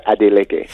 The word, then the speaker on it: Adeleke: I, I, tell people Adeleke.